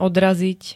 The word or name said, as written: odraziť